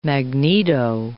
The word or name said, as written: magneto